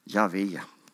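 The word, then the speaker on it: Jahve: Javé tror vi att det ska uttalas.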